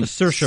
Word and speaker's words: Saoirse: From Saoirse Ronan. – Saoirse Ronan. – Saoirse Ronan.